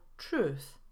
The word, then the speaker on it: truth: Przykład z tyry to słowo truth. Mówimy truth, a nie truth.